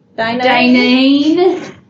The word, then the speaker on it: Daynene: Dainene? – Oh. – It's spelled D-A-Y-N-E-N-E. Dainene? – Dainene.